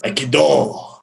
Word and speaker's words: Aikido: Aikido.